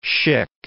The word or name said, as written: Schick